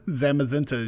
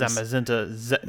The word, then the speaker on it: Zamazenta: Zimazentin. – No, that's yours. That's the shield Pokemon. Zamazenta is yours. – Zamazenta.